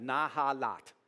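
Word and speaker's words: Nahalat (נחלת): It's actually the word nahahat. Not not a lot, but sounds like that. Nahalat. N A, let me spell it for you, N A H A L A T, not halat.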